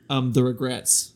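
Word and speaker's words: The Regrettes: The Ragrats? – No. The Regrets is like a female regret. You know how it's a dude and dudette? – Oh, E-T-T-E. – Yeah, so it's E-T-T-E-S.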